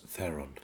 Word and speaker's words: Theron: Well, it's pronounced Theron.